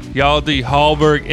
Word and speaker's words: Hjalte Halberg: We finally have Hjalte Halberg in the building.